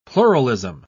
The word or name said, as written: pluralism